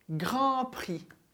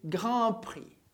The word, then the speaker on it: Grand Prix: Comentário que até hoje o brasileiro Ayrton Senna é o piloto com mais vitórias desse Grand Prix. Mas deixemos de saudade, vamos analisar as palavras. Tanto grand quanto prix terminam por consoantes. Grand com D e prix com X. Mas nenhuma delas vai ser pronunciada.